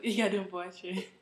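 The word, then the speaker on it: Yiadom-Boakye: Lynette Yadumboche. – Oh, no. – I can only say that because I'm Ghanaian, so I know okay. It's supposed to sound like Yadum. Yadumboche.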